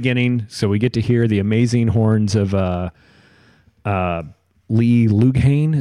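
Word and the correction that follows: Loughnane: Is that how you say his name? – Lee Lochney. – Lee Lochney. – Lochney. – Lochney. – I think it's Lochney.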